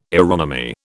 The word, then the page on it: aeronomy: British English